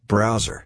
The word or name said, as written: browser